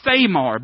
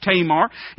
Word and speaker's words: Tamar: It looks like Thamar, but it would be pronounced Tamar.